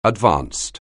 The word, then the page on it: advanced: anglais